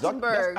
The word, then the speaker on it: Zuckerberg: It's Zuckerberg. – I said that, right? – Yeah. You said Zuckerberg. – No, I did not. – I didn't hear it. I thought you said Zuckerberg.